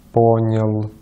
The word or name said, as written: понял